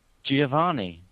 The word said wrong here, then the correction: Giovannoni: Giovannoni. – Giovannoni.